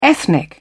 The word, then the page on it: ethnic: British English